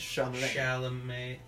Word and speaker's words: Chalamet: It's like French, Chalamet. – Chalamet. Well, there's an L before the M.